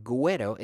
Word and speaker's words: güero: And güero is spelled G-U with two dots over it, E-R-O, güero.